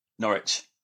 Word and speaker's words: Norwich: Norwich.